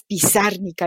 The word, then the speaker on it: Pizarnik: Es, un poemazo fantástico de Alejandra Pizarnik. Yo, siempre le pongo el acento en la, en la última I, pero es Pizarnik, Alejandra Pizarnik.